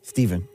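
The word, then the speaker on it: Stephen: Rick and, is it Steven or Stefan? – Steven. – Because it's spelled with a PH.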